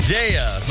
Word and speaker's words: Daya: Daya. – That's right. – Actually, it's pronounced Daya, but that's good enough.